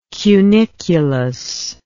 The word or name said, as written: cuniculus